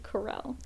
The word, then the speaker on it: Carell: Okay, I have been informed by a listener. Thank you, listener. That it is pronounced Steve Carell. – Carell? Shit. Yes. I said Carell the entire episode, didn't I? – Yeah.